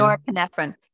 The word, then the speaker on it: norepinephrine: Norepinephrine.